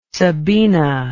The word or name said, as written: Sabina